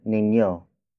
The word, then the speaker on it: ninyo: Lastly, we have niño. Niño. Niño. Niño is spelled as N-I-N-Y-O.